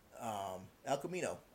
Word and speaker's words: El Camino: Now, obviously, one on Netflix coming up the week after that, El Camino, I believe you pronounced that right.